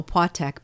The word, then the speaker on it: Opłatek: Opłatek. I know, Opłatek, because nothing that in Polish is pronounced how it looks.